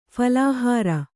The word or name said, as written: ಫಲಾಹಾರ